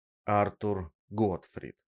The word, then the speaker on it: Артур Готфрид: Артур Готфрид. Ударение на А.